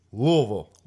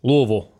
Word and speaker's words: Louisville: Excuse me. Louisville. – Louisville. Say it right.